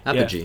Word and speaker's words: apogee: So the New Left gets it going where it reaches its fine point, where its real, how do you pronounce the word, apogee? – apogee. Yeah.